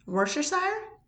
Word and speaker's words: Worcestershire: And I can't say the delicious sauce that starts with a W-O-R. – Worcestershire? – Is that actually how you say it? – That's how I say it. – Or are you just saying it confidently to fuck with me? – That's how I've always said it.